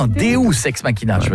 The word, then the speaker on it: Deus ex machina: Deux ex machina. Deus ex machina. – Ah, parce que deux, j'aurais dit que c'était deux ex que t'appelles machine. – Non, ça se prononce Deus ex machina.